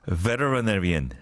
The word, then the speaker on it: veterinarian: And again, if you pronounce it the British way, it's much easier. But it's those American, you know, pronunciation that you know treats T as L, veterinarian.